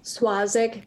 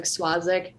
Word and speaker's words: Swazik: Swazik. It's pronounced almost like an SW, like Swazik.